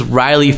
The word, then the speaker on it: Ryleigh: My family, like my name is Riley Fitzgerald. Like that's a fucking strong, like, and it's spelled like R-Y-L-E-I-G-H, which is the Gaelic spelling, right? – And like it's strong ass Irish name.